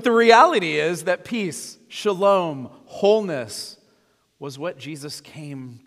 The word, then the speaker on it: Shalom: Shalom, which is the customary Jewish greeting or for farewell.